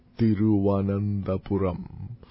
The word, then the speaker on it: Thiruvananthapuram: It has been known this way by the record, but it is now pronounced as Thiruvananthapuram.